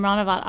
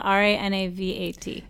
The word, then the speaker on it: Ranavat: Yeah, it's just my last name, Ranavat. R A N A V A T.